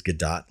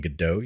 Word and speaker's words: Gadot: And yes, it is Gadot, listeners. If you've been saying Godot, you've been saying it wrong.